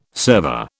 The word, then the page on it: server: British English